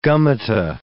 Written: gummata

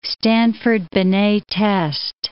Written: Stanford-Binet test

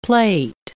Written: plate

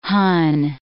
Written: Hun

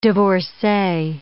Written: divorcee

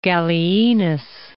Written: Gallienus

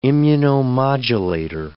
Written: immunomodulator

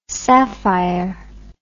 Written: safire

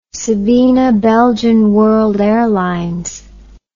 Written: Sabena Belgian World Airlines

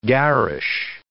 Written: garish

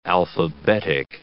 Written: alphabetic

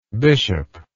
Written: bishoplike

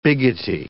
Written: biggity

